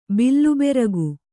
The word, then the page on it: ಬಿಲ್ಲುಬೆರಗು: bill beragu